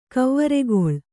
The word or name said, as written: ಕವ್ವರಿಗೊಳ್